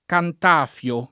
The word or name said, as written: Cantafio